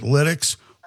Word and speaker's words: Lytx: It's LYTX, okay? And everybody pronounces it LIDEX. Why don't we call it LIDEX? – Right.